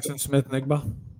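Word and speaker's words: Smith-Njigba: Smith-Nigba.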